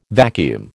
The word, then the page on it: vacuum: American English